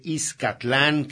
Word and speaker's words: Izcatlán: que en realidad debe de ser o pronunciarse Izcatlán, que era como se pronunciaba antiguamente.